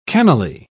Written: cannily

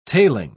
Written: tailing

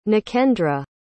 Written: nakendra

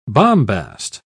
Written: bombast

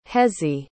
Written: hezzie

